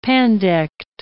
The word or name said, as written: pandect